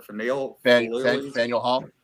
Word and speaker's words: Faneuil: The only place I've gone to in Boston is Karaoke Corner at Finale Square. I always say that wrong. Finale, Schlamazel. How do you say that? – Flegal. – No, it's Finale Square. – Faneuil Hall. – Faneuil Hall. Thank you.